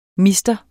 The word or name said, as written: mr.